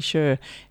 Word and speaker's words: Cher: And we'd started off with, is it Sher or Cher? I can never remember. I think I said Cher and it should be Sher.